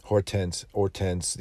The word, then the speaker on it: Hortense: And the same went for this poor girl, Hortense, you don't pronounce the H, but very French name.